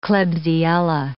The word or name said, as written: klebsiella